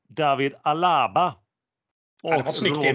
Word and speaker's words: Alaba: David Alaba, Robert Lewandowski och Mario Gomez.